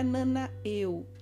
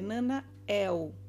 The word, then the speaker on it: Nanael: O final dele que é importante observar, ele não é Nanael, é Nanael, com esse E agudo e com L no final ok?